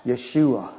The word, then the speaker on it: Yeshua: And it's pronounced Yeshua, a word some Christians actually use today.